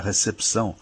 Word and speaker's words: recepção: A gente escreve recepção e pronuncia recepção. A gente lê o que está escrito. Esse P de pato, diferentemente do que a gente diz no dia a dia, não é mudo, ele é lido, ele é pronunciado.